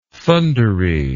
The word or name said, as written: thundery